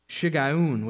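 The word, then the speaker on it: Shiggaion: There's a prayer of Habakkuk the prophet according to Shigayun, which is how you actually pronounce that. Ryan and I looked it up because it's a Hebrew word. It does not look like it's pronounced that way, but it is.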